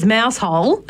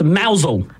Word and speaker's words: Mousehole: Oh, it's actually a mousel. – Mousel. – But it's spelt mousehole. – It is spelt like mousehole.